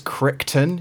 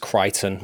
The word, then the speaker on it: Crichton: It's based on a book by Michael, I think it's Crichton, you pronounce it? I don't know. It's C-R-I-C-H-T-O-N. – For some reason in my head, I read it as Crichton, Michael Crichton. – Crichton, Crichton, whatever, yeah.